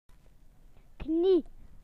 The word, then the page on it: knee: British English